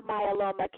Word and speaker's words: myeloma: is it the Myola Cancer Awareness? Am I saying that correctly? – So, yes, it's Multiple myeloma cancer, yes.